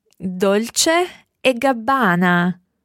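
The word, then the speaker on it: Dolce & Gabbana: What about the other one that's spelled P-R-A-D-A? – Prada. Prada. – And then the one that's two people's names? – Dolce e Gabbana.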